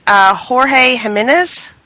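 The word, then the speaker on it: Jorge Jiménez: I think I'm saying his name right. Jorge Jimenez? – Yep. – Mm-hmm. – Good pick. Yep. Very right.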